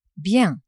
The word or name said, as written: bien